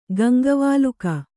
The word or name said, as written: ಗಂಗವಾಲುಕ